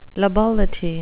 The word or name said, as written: lability